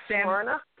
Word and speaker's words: Smyrna: How do you pronounce that part of Georgia? – That's Smyrna. Smyrna, Georgia.